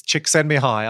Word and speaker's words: Csikszentmihalyi: So flow is a concept, and it's often talked about these days in the circles of positive psychology, but a psychologist called Chimineski, you know, I would have mispronounced it and I apologize. – It took me 3 years doing my master's. Csikszentmihalyi.